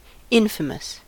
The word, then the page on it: infamous: American English